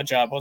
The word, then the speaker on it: Ojabo: I was thinking somebody else on the Bears, but yeah, Ojabo is how you say that.